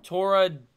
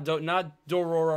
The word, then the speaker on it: Toradora: Is it Dora de Horo? – Toradora. Toradora. – Dorora. – Not Dororo or any of those. Toradora. No. I don't think so. But there is a Toradora.